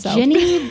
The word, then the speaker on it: Jinni: And it's Ginny, but it's like genie, but it's not spelled the same. Anyway, it's Gollum, G-O-L-E-M, and the Ginny, J-I-N-N-I, by Helene Beckwith.